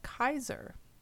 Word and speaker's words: Caesar: And in Classical Latin pronunciation, the word Caesar is actually pronounced with a hard C or K sound for the letter C, and it's pronounced Kaiser. And the A-E makes that I sound.